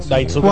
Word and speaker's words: Daisuke: Daisuke cuando, Matsusaka. los, no se pronuncia la, la U en ese caso.